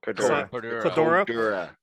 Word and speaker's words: Cordura: that can sew true Condor and as well a con. How do you say it? – Cordura. There's no N in there, and as well as making patches like a 7x7 patch.